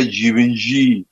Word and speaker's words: Givenchy: Hubert es el nombre, Givenchy que se pronuncia Givenchy.